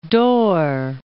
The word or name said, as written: Dorr